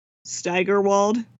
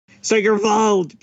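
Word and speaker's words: Steigerwald: Well, even though my Yinzer cousin pronounces it that way, I think officially Staggerwald. So a little less on the stagger. I mean, I don't know how German you want to get here. Staggerwald, please.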